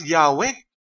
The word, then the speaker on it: Yahweh: The letters YHWH used in Hebrew manuscripts of the Old Testament to denote the divine name, customarily vocalized as Yahweh.